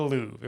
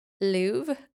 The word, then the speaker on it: Louvre: I'm pretty sure it's the Louvre. If we're wrong, let us know in the comments. – Okay. Anywho, the R is silent.